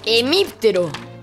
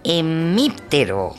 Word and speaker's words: hemíptero: Ve, lo que estás buscando es sin H. A ver, espérame. No fastidies. – Emíptero. con acento en la I.